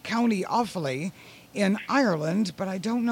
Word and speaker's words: Offaly: We're in Offaly. – Offaly. Okay, that's... – Yes. O-F-F-A-L-Y.